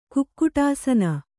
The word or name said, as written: ಕುಕ್ಕುಟಾಸನ